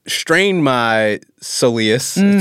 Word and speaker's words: soleus: Let's double click on the, how do you say it? Soleus? – Soleus. – Soleus. American, I say soleus.